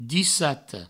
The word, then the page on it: dissata: Provençal; dissato